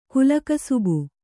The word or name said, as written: ಕುಲಕಸಬು